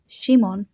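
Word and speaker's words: Shimon (שמעון): In Aramaic, his name would have been pronounced Shimon, and in Greek, Simon.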